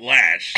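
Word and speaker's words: Loesch: She pronounces it Lash. So it must be, although I don't know how anybody takes those letters and put them together into Lash.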